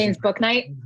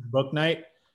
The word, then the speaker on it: Bouknight: James Bouknight. – Bouknight.